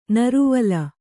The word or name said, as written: ನೀರುವಲೆ